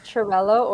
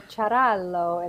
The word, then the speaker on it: Ciarallo: It's Cerello or Cerello in Italian.